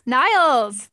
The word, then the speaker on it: Niels: Niels, is that how you say it? N-I-E-L-S. Niles, there we go.